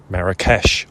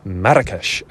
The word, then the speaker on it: Marrakech: Marrakech, often spelt with an SH at the end, as in sh, often spelt with a CH in the end, as in ch, although actually both are pronounced the same, as in Marrakech, or Marrakech, as the Moroccans say.